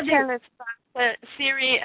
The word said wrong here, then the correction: Sri: I know I'm saying his name wrong, Siri Swamiji? – It's Sri, that's Sri?